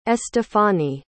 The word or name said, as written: estefani